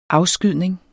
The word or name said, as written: afskydning